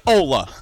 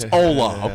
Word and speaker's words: hola: It's hola. The H is silent. – 100%. – Believe me, I'm a Spanish minor. It's hola, okay?